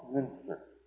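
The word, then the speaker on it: Müntzer: This man's name was Thomas Münzer, capital M-U-N-T-Z-E-R. There's two little dots over the U. So the U is pronounced with, what they call a German umlaut, Münzer, M-U-N-T-Z-E-R.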